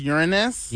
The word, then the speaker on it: Uranus: No, he's right. That's how you pronounce it. – Uranus. – What? – Yeah. – Oh, it's not the other way. – Yeah, you were going to say it Uranus. – Yeah. No, it's Uranus.